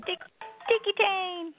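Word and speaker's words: Tiki Taane: We're going to play, how do you say this name up there? Is that Tiki Tane? Tiki Tane? – Tiki Tane.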